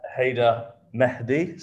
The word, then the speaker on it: Haider Mehdi: Haider Mehdi.